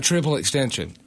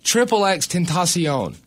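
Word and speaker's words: XXXTentacion: It's not Triple Extension. It was Triple X Tentacion.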